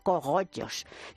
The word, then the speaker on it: cogollos: Dice, ¡ay, mira, me encantan los cobollos! Y le dice mi cuñada, ¡uy, tía, perdona, pero es que no se dice cobollos, se dice cogollos!